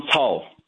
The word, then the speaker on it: Cao: Is it Pastor Cao? – Yeah, Pastor John Cao.